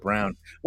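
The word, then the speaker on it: Braun: He's of German descent, I guess, but he pronounces it Brown.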